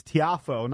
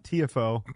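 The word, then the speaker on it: Tiafoe: Yeah, I believe Francis Tiafo, not TFO, had the game-winning serve.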